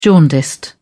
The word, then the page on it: jaundiced: British English